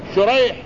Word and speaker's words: شريح: الأول بالشين المعجمة شريح ابن النعمان والحاء المهملة، وهو تابعي رحمه الله يروي عن علي رضي الله عنه.